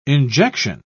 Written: injection

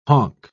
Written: honk

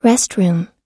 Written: restroom

